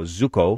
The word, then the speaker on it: Tzuco: Zucco, is that how you pronounce T Z U C O? – Zucco.